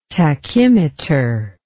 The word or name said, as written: tachymeter